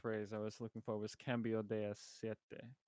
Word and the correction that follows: aceite: Siete? I butchered it. Cambio de Aceite.